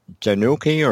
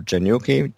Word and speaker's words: GENUKI: And the other one for research help that I found really useful, I pronounce it Genuki or Genuki, G-E-N-U-K-I.